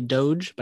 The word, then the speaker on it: Doge: Is it Doy? I mean, it's spelled doggy, right? – I think you're supposed to say Doge, but I just call it doggy.